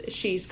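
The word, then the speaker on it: Sidhe's: My trilogy is the Keening Trilogy, and the first book is She's Call, and it's spelled S-I-D-H-E apostrophe S, because that's the Celtic spelling for the fairy folk.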